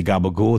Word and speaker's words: gabagool: That's how they say it. They say gabagool. It's actually, I think, a capicola maybe would be how a layman would say it, but it's gabagool in The Sopranos parlance.